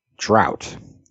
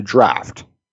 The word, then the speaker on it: drought: Same with a term we sadly use often down here, drought. It's pronounced with an out in the middle as opposed to draft.